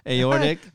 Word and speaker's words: aortic: Yeah, that's why I started like, I knew I instantly pronounced it wrong. Aortic.